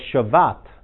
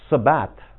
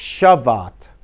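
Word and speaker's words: Shevat: But see how they spell it? Sabbat, right there? It's really, once you learn Hebrew, that is an SH. Yeah, so it's Shavat.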